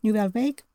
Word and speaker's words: Nouvelle Vague: Nouvelle Vague, was also cooking up a cover of Love Will Tear Us Apart, and a cover of Blue Monday.